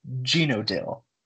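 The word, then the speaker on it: Zhenotdel: Apparently it is pronounced Genodil, which I'm still probably gonna butcher because I don't speak Russian.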